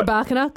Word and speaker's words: Rybakina: I've gone Rybinka. Not quite. – Rybakina, close. – Rybakina, Elena, for short.